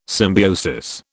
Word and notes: symbiosis: British English

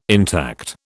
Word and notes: intact: British English

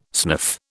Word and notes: sniff: British English

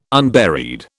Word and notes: unburied: British English

